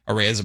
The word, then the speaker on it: Arraez: Arraez appropriately rhymes with prize, and he's just that for contending teams that recognize bat-to-ball skills go a long way in the postseason.